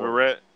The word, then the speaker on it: Verrett: Veritek, how you say his name? He just got hurt, and – San Diego. – Jason Verrett? – Yep.